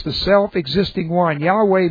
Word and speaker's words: Yahweh: So I pronounce it in the Hebrew, Yahweh.